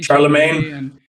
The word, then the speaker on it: Chalamet: Timothy Chalamet.